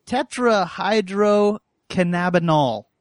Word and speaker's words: tetrahydrocannabinol: Tetrahydrocannabinol. – Cannabinol, not cannabinoid.